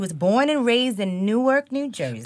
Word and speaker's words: Newark: We say it like fork with an N. – Yeah. – It's Newark.